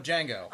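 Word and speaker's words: Django: No, it's a different Jango. – Different movie, different genre. – There's no D. – Oh, I know. The D is silent.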